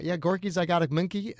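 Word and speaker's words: Gorky's Zygotic Mynci: But Gorky Zygotic Minky is how they pronounce it, but it's spelled different.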